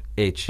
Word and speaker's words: Aytche: H. – That's how it's pronounced? – Yeah, it's spelled A-Y-T-C-H-E.